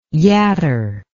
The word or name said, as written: yatter